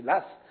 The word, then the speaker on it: Law: Le W se prononce S. Est-ce qu'il y a des Écossais dans la salle? Non. Alors, des Écossais m'ont affirmé que ça se prononçait Lass. Moi-même, je disais Law et ils m'ont corrigé.